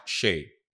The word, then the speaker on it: Seyi: Okay, it is S-E-Y-I, but it's pronounced like Shay Butter. – S-A-Y? Like Shea Butter. – S-E-Y-I, but it's pronounced Shea. Like the Shea Butter.